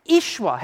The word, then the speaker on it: Yeshua: Sometimes people say Yeshua today, but that's not how you pronounce it. The Y and the E are E. Yeshua.